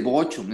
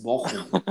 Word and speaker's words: Bochum: No, pero no se dice Bochum, es Bochum. Bochum.